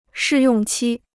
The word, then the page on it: 试用期: shì yòng qī